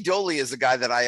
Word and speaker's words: Doley: It's L-A-C-H-Y is his first name, pronounced Lucky, D-O-L-E-Y, Dolly, and the Australian blues rock guy.